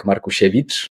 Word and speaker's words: Markusiewicz: It's Jacek Markusiewicz. It's a Polish surname.